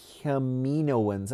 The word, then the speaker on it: Kaminoans: And I don't think we've mentioned before, but there's a kid, a child character, that is seen with the Kaminoans.